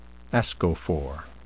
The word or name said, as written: ascophore